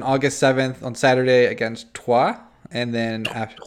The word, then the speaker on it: Troyes: Yeah, well, it's an R, but that's a French R. It's really hard to say. It's Troyes.